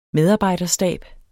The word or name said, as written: medarbejderstab